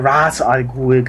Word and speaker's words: Ra's al Ghul: Also auf jeden Fall ist das die erste Geschichte, wo Batman auf Ra's al Ghul trifft. Oder Ra's al Ghul, ganz neumodisch. Ist ja angeblich die korrekte Aussprechweise.